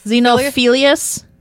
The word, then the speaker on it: Xenophilius: Well, it starts with an X, but is that silent? – Xenophilius? That, I think, is how it's pronounced. – So it's spelled with an X, but pronounced with a Z? – Like xylophone.